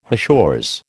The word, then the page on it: hachures: American English